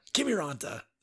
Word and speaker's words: Kiviranta: Kirvanitana? I don't know, dude. So no name, right? Like, I was going to say, I feel like that's not even close to the pronunciation. – Kiviranta. – Sure. – Yeah. – That sounds better. That's closer. That sounds Finnish.